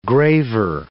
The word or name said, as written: graver